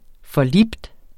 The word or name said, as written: forlibt